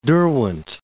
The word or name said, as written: Derwent